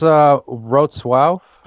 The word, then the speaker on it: Wrocław: Wrocław? Is it? – It's pronounced Wrocław.